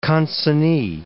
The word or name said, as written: consignee